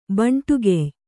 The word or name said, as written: ಭಂಡುಗೆಯ್